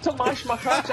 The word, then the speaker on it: Tomáš Macháč: He can get to number one in the world, Martina, if he wins the title here, facing Tomáš Macháč of the Czech Republic. Did I say that right? – Close enough. Tomáš Macháč.